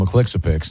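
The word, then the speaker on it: Mxyzptlk: I called him Mr. McClixapix. – See, you nail it.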